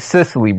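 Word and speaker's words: Cicely: CCO Tyson. – You said three. I got you on recording saying three times. – He said it wrong. It's Sicily, brother. Sicily.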